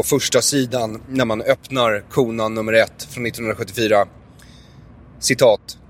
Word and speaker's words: Conan: Ja, så jag kände att jag var tvungen att köpa den, plus att jag älskade Konan som barn. – det är så kul att du säger Konan också. Han heter väl ändå Conan the Barbarian, inte Konan. Konan! jag uttalar det på svenska.